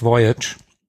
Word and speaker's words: Voyage: Ja, ich habe es tatsächlich damals immer französisch irgendwie ausgesprochen, aber natürlich hast du recht, Voyage.